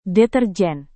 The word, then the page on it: deterjen: Indonesian